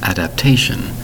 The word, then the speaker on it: adaptation: Today's word is adaptation. Adaptation is four distinct syllables, spelled A-D-A-P-T-A-T-I-O-N, adaptation.